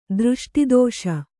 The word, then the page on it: ದೃಷ್ಟಿದೋಷ: dřṣṭi dōṣa